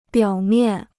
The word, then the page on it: 表面: biǎo miàn